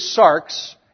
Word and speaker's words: sarx: So the word in Greek is sarx, and that's sigma, alpha, rho, and xi, like the X in axiom, okay? Those are the letters, and they spell it S-A-R-X, and that's sarx.